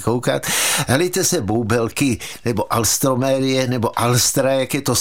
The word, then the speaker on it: Alstroemeria: Alstroemeria, no a čte se to alstromérie, spíš jako dlouze v prostředku to, ale to je jedno.